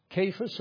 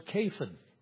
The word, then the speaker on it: Cephas: But it's Cephas or Cephan, more like our Kevin in actual pronunciation.